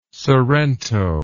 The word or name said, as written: sorrento